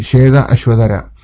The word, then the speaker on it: Shvetashvatara: So Prabhupada is correcting the pronunciation and says, Shveta Ashvatara.